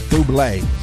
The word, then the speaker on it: Bublé: And if you are celebrating a birthday today, you share your birthday with Michael Bubble. Oh, wait, that's Bublé. Sorry, I mispronounced it.